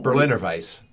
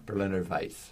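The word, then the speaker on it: Berliner Weisse: It's a Berliner Weiss, which is what this is. – How do you say that? What's that? – Berliner Weiss. The W is a V. – Okay. – Berliner Weiss.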